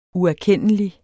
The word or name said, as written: uerkendelig